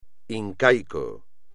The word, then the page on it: incaico: espagnol